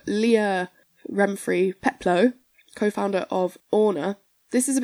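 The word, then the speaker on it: Ohne: So Orna is a German word meaning without, and this article said it's pronounced Orna. – Mm-hmm. – But then in an interview with The Woman, Leah Renfrey Peplow says, we pronounce it on.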